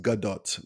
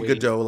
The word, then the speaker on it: Gadot: It's well, and I love her and her last name is pronounced Gadot, by the way, just so you know. – I thought that's what I said. What did I say? – Gadot. – Most people say Gadot like it's French, but it's Gadot.